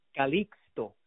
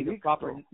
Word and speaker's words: Calixto: It's actually pronounced Calixto, which is actually Calixto. – the proper way, with a C and with the X.